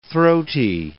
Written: throaty

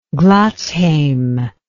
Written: Gladsheim

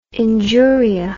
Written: injuria

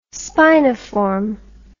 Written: spiniform

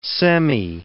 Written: semi

